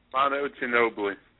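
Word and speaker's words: Manu Ginóbili: Manu Ginobili.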